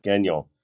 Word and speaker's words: Gagnon: You're over in Gagnon. Am I pronouncing that right? – Gagnon. – Gagnon. It's French. No, it's French. Gagnon.